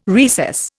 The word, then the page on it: recess: Indian